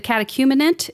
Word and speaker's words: catechumenate: The catechumenate, am I saying that right? – You nailed it.